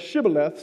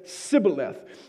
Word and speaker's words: Shibboleth: Now, the dialect that Ephraim spoke, they didn't pronounce the H when they said Shibboleth, so they said Sibboleth, which was an obvious indicator that they were of Ephraim and they were not Gileadites.